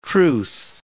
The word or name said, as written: truth